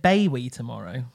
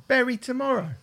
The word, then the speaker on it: Bury Tomorrow: Is it my hearing, or is it your pronunciation? I thought you said Bowie Tomorrow. – Bury Tomorrow.